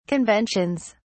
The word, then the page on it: conventions: British English